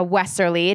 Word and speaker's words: Wesserle: So without further ado, I want to introduce our guest today, Maria Westerly.